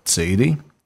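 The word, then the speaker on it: Tseirem (צירה): Até recentemente, muitos judeus fora de Israel pronunciavam Tseirem como Ei. Contudo, hoje a pronúncia israelense normativa de Tseirem é o som de E em pé e tem sido adotada pela maioria do mundo judaico.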